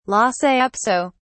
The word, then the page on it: lhasa apso: British English